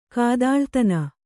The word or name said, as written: ಕಾದಾಳ್ತನ